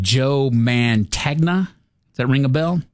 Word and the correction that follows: Mantegna: Montagna. – Montagna. Mm-hmm. I pronounced it phonetically.